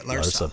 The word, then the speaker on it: Larsa: But yeah, I couldn't believe it because his woman, Larsa Pippen, which Larsa? – Sounds terrible. Yeah, that's how you say it, Larsa.